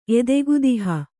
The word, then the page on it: ಎದೆಗುದಿಹ: Kannada